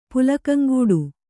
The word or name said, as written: ಪುಲಕಂಗೂಡು